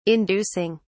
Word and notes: inducing: British English

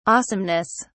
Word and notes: awesomeness: British English

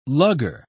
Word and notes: lugger: British English

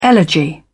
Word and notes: elegy: British English

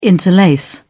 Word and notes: interlace: British English

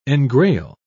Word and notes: engrail: British English

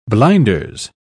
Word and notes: blinders: British English

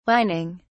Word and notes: wining: British English

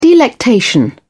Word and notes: delectation: British English

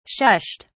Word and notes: shushed: British English

